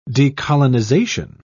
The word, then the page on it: decolonisation: British English